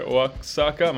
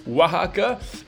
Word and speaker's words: Oaxaca: Al primo posto invece c'è un'altra città messicana che è Oaxaca, che si scrive Oaxaca, ma si dice Oaxaca, e che è stata eletta appunto città più bella del mondo nel 2020.